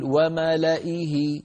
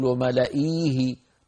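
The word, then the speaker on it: وملإيه: إذن الياء في كلمة وملئه لا تنطق، يعني لا نقول وملئيه، نعم، بل نقول وملئه، إه همزة مكسورة بعدها ها، سواء وقفنا على هذه الكلمة أو وصلناها بما بعدها.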